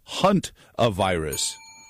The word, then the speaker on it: hantavirus: Down on the Treasure Coast, says, you're pronouncing it wrong. It's hunt-a-virus.